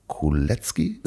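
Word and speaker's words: Koletzki: Und ich habe mir heute, ähm, oder heute Morgen, ähm, die Folge angehört mit DJ Oliver Kuletzki. Ist ja. das richtig mhm. ausgesprochen? Mhm. – Ja, genau.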